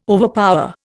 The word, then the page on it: overpower: Indian